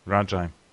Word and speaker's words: Rajai: Raji, Rajai, Raji Davis. – Rajai. – Rajai.